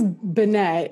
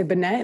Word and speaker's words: Benet: Um, well, mine's Benet, and people say Benet, and then oh. they'll go, "Oh, is your last name?" Or uh, they'll say, "How do you say your last name?" I'll say Benet, and then they'll go Benet no, Bennett, like, why are you trying to change what I just said to you?